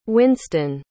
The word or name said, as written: wynston